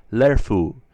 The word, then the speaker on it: lerfu: E is always to be pronounced E, and usually this is easy. But if you're not careful, words like lerfu, spelled L-E-R-F-U, meaning alphabetical character, verba, spelled V-E-R-B-A, meaning child, or kerlo, spelled K-E-R-L-O, meaning ear, sometimes sound like they are spelled lerfu, verba, curlo, with the sound.